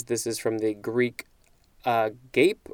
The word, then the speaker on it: agape: Agape. That is how this one is pronounced, A-G-A-P-E. The pronunciation guide is telling me this is agape.